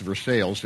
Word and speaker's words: Versailles: And Versailles, I know, I would learn 2 years later that in Ohio it's pronounced Versailles. Everywhere else it's pronounced Versailles and it's spelled the same way.